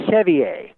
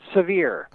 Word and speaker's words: Sevier: No, it's just, it's actually, in French it would be Sevier, you see, but they pronounce it Sevier.